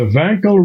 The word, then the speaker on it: Wankel: The Wankel rotary engine. – Great guess. – With authority. – With authority. – I like your pronunciation. – Thank you. Thank you very much. – W pronounced as a V.